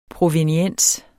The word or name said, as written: PROVENIENSER